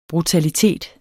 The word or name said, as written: brutalitet